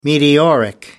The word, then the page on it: meteoric: American English